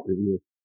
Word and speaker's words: Revere: Gotta say it like that. Revere.